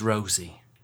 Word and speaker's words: Rosie: My name is Rosie. Kind of funny now that it rhymes with nosy.